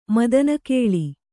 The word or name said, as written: ಮದನಕಳೆ